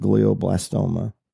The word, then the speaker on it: glioblastoma: The review that you left was that you had been diagnosed, Brother Jeremy, with glioblastoma.